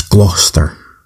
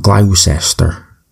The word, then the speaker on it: Gloucester: It's Gloucester. It's not Gloucester or Gloucestershire or Gloucester or Gloucester.